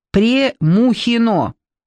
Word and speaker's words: Премухино: 18 деревень и хуторов входило в поселение Примухино, и называлось оно тогда Премухино». Он делает ударение на букве «Е».